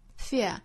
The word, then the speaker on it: fear: 那后面这个 fear 呢，我们要注意哈，这个词英音和美音的发音也是有差别的。英音呢会发成双元音的 ear, fear。美音呢我们仔细听哈，其实更紧一些，发成 fear。